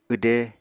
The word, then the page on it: wde: Apatani